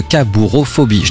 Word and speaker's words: cabourophobie: Mais peut-être que vous êtes atteint sans le savoir de cabourophobie. Je l'ai bien prononcé, ça va, pas d'erreur? – Ouais, c'est bien.